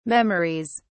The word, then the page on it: memories: British English